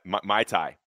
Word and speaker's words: Muay Thai: Am I saying that right? Muay Thai. There we go.